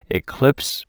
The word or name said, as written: eclipse